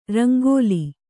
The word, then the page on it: ರಂಗೋಲಿ: rangōli